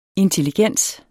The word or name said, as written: Intelligens